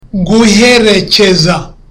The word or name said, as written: guherekeza